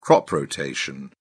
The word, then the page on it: crop rotation: British English